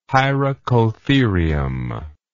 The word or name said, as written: hyracotherium